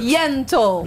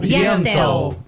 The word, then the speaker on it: Yentl: Ó, a querida Alessandra Maestrini, ela tá em peça, ela tem peça, que é a Ente. Ent in Concert. – Yentl. – Yentl. Perdão, eu vou fazer com pronúncia Yentl in Concert.